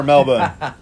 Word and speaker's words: Melbourne: Melbourne or Melbourne? – Well, Melbourne is the local pronunciation.